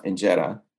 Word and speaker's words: injera: Yeah, it's uh, it's pronounced injera. It's spelled I N J E R A and that is typically found in Ethiopia um, and Eritrea, which is sort of in uh, north uh, northeastern Africa.